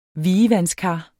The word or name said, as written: vievandskar